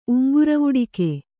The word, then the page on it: ಉಂಗುರವುಡಿಕೆ: uŋguravuḍike